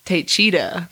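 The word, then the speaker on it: Taycheedah: On November 3rd, 1973, at 11:30 a.m., a farmer named Gerald Braun was returning home in his tractor in Taycheeda, Wisconsin. – Taycheeda. – It's spelled with like a D. Taycheeda.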